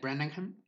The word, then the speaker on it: Branagh: Kenneth Branagh, se pronuncia así, bueno.